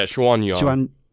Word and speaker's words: Xuanyuan: And this is from, how do you pronounce this? Legend of Xuanyuan? – Yeah, Xuanyuan.